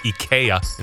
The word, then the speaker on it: IKEA: And IKEA, which was actually pronounced IKEA in Sweden. – IKEA.